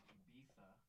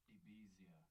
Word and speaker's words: Ibiza: Someone in Spain would refer to the island as Ibiza. – Yeah. – Yes. – Even though they'll still spell it as Ibiza.